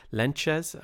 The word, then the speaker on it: Lentjes: Today we look at some of the psychological research into psychedelics and speak with Alexander Lentjes, am I pronouncing that right? – That's correct.